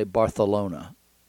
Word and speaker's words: Barcelona: So they would say Barcelona, like a TH instead of a C.